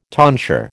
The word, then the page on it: tonsure: American English